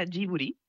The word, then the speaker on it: Ghibli: En japonés se pronuncia Ghibli. Uh-huh. Porque ellos no tienen el sonido de la L, pero es una historia bastante graciosa porque el nombre lo elige Hayao Miyazaki, y lo elige por la palabra japonés.